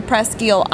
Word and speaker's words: Presque Isle: But the Presque Isle I am used to is near Alpena, Michigan, and it's pronounced Presque Isle. – Yeah, because it's French.